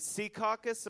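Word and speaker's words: Secaucus: which broadcast for many years on WOR in Sea Caucus.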